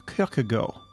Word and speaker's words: Kierkegaard: The Danish, I understand, also pronounce his last name as Kierkegaard.